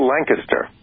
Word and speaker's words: Lancaster: Which he's lucky it didn't become B-A-I-R-D and then become B-I-R-D. So. – place in Pennsylvania, locals pronounce it Lancaster. – Yep. – Because it came originally from Lancashire in England. That's where the name came from. But most people looking at it would call it Lancaster.